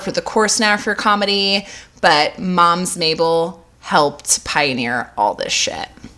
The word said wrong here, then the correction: Mabley: Mabley. I'm sorry, Mabley.